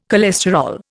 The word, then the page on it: cholesterol: Indian